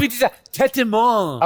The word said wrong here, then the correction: Tête de moine: Tête de moine.